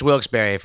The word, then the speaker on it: Wilkes-Barre: It's Wilkes-Barre.